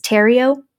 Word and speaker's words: Theriault: It's spelled like T-H-E-R-I-A-U-L-T, but it's Terrio.